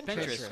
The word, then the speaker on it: Pinterest: What's Pinterest, and how can it help small business? Did I say it right? – Pinterest. – Great recipes on Pinterest. – Interest with a P.